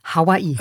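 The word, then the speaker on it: Hawaii (Hawaiʻi): Hawaii, korrekt nativ geschrieben und nativ ausgesprochen ist übrigens, jetzt kommt das besagte Okina, Hawaii.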